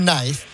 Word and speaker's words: knife: The letter K in knife. – Yeah. – Is silent because people used to pronounce it in Old English and Old Norse. And the spelling stayed the same even after the pronunciation changed.